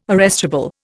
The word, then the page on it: arrestable: Indian